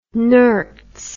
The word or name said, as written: nurts